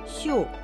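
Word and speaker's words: Сю (绣): Сю – это вышивка, читается в четвертом тоне.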